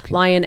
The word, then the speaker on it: Lyon: Lyon, L-Y-O-N. – L-Y-O-N. Okay, yes. So Kate Lyon Osher. – You spell it wrong, you know. – It's like Lyon, it's like Lyon Estates from Back to the Future.